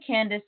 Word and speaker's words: Candace: And Candace is spelled C A N D A C E.